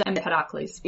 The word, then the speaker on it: Empedocles: It's Empedocles.